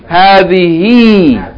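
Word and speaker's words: hadhihi (هذه): Hadhihi. Hadhihi sayyaratun. Okay, it's the feminine of hadha. It is pronounced hadhihi with a long vowel. Ha fatta alif. But the alif is omitted in writing.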